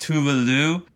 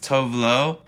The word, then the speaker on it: Tove Lo: Well, it's it's supposed to be pronounced Tuvalu, but she's embraced Tove Lo.